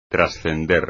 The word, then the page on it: trascender: espagnol